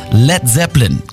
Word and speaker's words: Led Zeppelin: Led Zeppelin. Das A in Led ließ er einfach weg, damit es nicht wie Blei ausgesprochen wird.